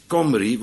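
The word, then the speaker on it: Cymry: En nog verder terug, ze worden genoemd Kimmeriërs of de Kimri, ook uitgesproken als Comri, want zo spreken de Welshmen het uit, hè? Die schrijven Kimri met een lange I, met een Y, en ze zeggen Comri.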